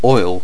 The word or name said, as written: oil